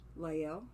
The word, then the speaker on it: Lael: I think her name is pronounced Hayisa Lael, L-A-E-L, Lael.